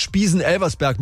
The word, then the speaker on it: Spiesen-Elversberg: Die wichtigste ist jetzt Spiesen-Elversberg mit der Betonung auf Elversberg.